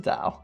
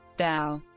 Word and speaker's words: dhow: Is it dhow? D-H-O-W? – I don't know what you're talking about. What's a dhow? – That's what your boat's called, isn't it? – Oh, the Defiant, I thought mine was called. – Not your actual boat. Like the... Yeah, I'm right. Google's going to tell me how it's pronounced now. – Dhow. – Dhow. Yeah, I was right.